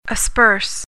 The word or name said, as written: asperse